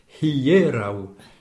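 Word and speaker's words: hieraŭ: Alors, attention à l'accent tonique. Hierau. Il y a trois syllabes dans ce mot. Hierau, hier. – Hierau, en fait, il n'y a que le E, la syllabe du milieu, l'avant-dernière, donc toujours, qui est accentuée.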